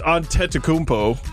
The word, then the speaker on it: Antetokounmpo: Yes, and Giannis Antetokounmpo. It's Antetokounmpo. – I was close.